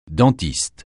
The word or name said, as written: dentiste